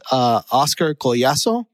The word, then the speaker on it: Collazo: and I said his name wrong. So I feel like I need to correct it. It's Oscar Collazo.